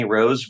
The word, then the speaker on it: Rose: My name's Kenny Rose. Rose spelled like the flower.